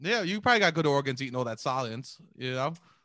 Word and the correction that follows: Soylent: Yeah, man, couldn't even pronounce it right. – Soylent. Yeah, what was Salient? – Oh, man.